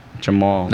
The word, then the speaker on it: Dzemo: D-Z-E-M-O. – Get out of here. – Yeah. What the fuck? – The D in Albanian makes a J sound.